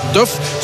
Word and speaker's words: Duff: Zo moeten we het blijkbaar uitspreken. Mahmet Duff, zoals de Duffkes van Welles vroeger, van Welles en Wee.